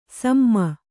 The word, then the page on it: ಸುಮ: Kannada